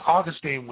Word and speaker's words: Augustine: And I define, you know, aberrant is something that Christians disagree on in good faith, as Augustine would say, or Augustine as some would pronounce it, in essentials unity, in non-essentials liberty, but in all things charity.